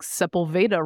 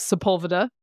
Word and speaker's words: Sepulveda: I made the mistake when I first got to L.A. of pronouncing things with what would be the accent in Spanish, like Sepulveda Road. Nope. – It's not. – That is Sepulveda.